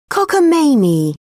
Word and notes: cockamamie: British English

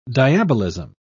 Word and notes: diabolism: British English

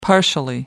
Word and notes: partially: American English

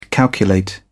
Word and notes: calculate: British English

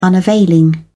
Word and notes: unavailing: British English

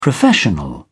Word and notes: professional: British English

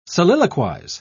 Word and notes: soliloquise: British English

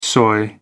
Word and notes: soy: British English

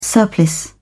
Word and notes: surplice: British English

